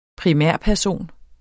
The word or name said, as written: primærperson